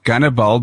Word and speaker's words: Gannibal: And then Gannibal, because Russians pronounce Hannibal as Gannibal.